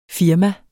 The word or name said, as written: firma